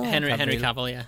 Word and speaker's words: Cavill: Am I saying his name right? Is it Cavill? – Henry Cavill, yeah.